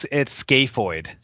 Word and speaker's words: scaphoid: And how do you pronounce that word? – Yeah, it's scaphoid.